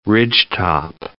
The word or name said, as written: ridgetop